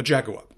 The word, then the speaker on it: Jaguar: A Jaguar. I'm pronouncing it the way those commercials from like 20 years ago just pronounced it to sound highfalutin.